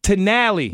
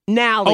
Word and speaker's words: Nally: To Nally. – No, no T. Just Nally. – Oh, it's Nally. – Nally. N-A-L-L-Y.